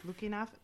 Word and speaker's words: Lukianoff: Lukianoff.